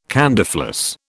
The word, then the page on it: candyfloss: British English